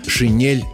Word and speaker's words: шинель: То есть русскому языку в принципе не свойственно сочетание твердый согласный плюс «э». В некоторых иностранных словах такое произношение может сохраняться, но обычно по мере освоения заимствованного слова русским языком «э» заменяется на «е». А в общем, просто запоминаем, «шинель» нужно произносить мягко, как в стихотворении Булата Акуджавы.